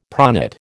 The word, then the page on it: pronate: American English